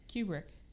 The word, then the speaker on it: Kubrick: Q, like cube, Rick. – Yeah, Kubrick. Kubrick? – They just give it a long U.